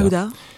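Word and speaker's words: Mawda: Je ne sais pas exactement comment ça se prononce, Maouda. – Maouda.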